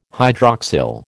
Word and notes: hydroxyl: American English

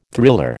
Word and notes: thriller: American English

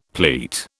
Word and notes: pleat: British English

